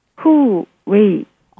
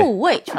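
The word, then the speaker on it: 후, 웨이입니다 (护卫): 지킬 호, 지킬 위를 쓰고 둘 다 사성입니다. 발음은 후, 웨이입니다. – 아, 좋아요. – 네, 헌하오. – 헌하오. 네. 그대로 가셔도 돼요. 네. 후, 웨이.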